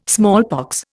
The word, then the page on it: smallpox: Indian